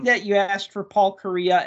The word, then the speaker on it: Kariya: Yeah, because if you hear Paul Kariya, I mean, the worst case scenario, you spell it like the country. Like, yeah, K-O-R-E-A. Like Korea. Yeah. – Kariya. But Kariya.